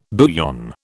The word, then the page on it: bouillon: British English